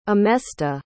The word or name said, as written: emesta